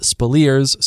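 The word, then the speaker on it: Speleers: It's spelled and pronounced Spaliers.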